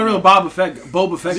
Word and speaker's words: Boba Fett: Next on the list, Boba Fett makes his return. That's a joke, right? – What? – You pronounce his name wrong. – Boba. – Boba Fett.